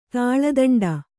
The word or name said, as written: ತುಲಾದಂಡ